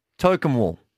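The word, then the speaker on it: Tocumwal: Thanks to the Club Tokemwall. Did I say it right? – Yeah, you did. You got it right.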